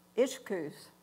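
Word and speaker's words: ischus: To the energizing, it says of, okay, I wrote this down, Michelle. Ishkus. – Okay. – That's how it's pronounced.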